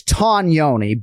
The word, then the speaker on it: Tognoni: So it's Tognoni, but he has been ubiquitously referred to as Chris Tognoni for so long that he's okay if people don't go with the correct Italian pronunciation, right. But it is Chris Tognoni, and yes.